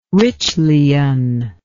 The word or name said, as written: Ritschlian